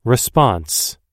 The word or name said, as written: response